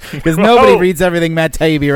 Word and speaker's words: Taibbi: Is that how you pronounce his last name? – Taibbi. – Taibbi. Okay, cool.